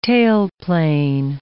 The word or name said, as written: tailplane